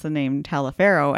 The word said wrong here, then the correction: Taliaferro: and then I got the whole, it's Tolliver.